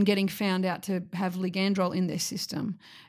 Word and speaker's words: ligandrol: And she says the ligandrol. – Yeah, that's how you pronounce it.